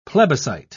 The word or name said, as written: plebiscite